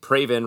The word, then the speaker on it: Praven: Someone named Praven rhymes with Raven at PPK007 recommended a song that's been around since the early 80s, but I did not hear until recently.